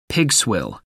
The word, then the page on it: pigswill: American English